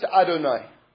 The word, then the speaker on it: Adonai: It's pronounced Adonai.